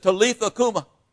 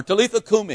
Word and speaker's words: Talitha kumi: He said, Talitha kuma. Or Talitha kumi, if you want to be a little better, pronounce it a little bit better.